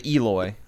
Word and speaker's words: Eloi: Yeah, the Eloi.